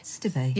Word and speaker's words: Stibbe: And then I'm also reading how do you pronounce her? Nina Stibbe? – Stibbe. – Yeah, you should say that. I'm never sure how to say that.